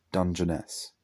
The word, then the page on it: Dungeness: English